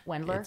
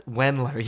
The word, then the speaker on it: Wendler: He's like, Kenny, I'm on the, you can correct me if I'm saying this wrong, the Wilder program, the 531 method. – Wendler? It's Wendler. Yeah, there you go.